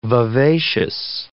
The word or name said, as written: vivacious